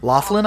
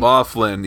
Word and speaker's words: Loughlin: Loughlin, I believe. – Loughlin, yes.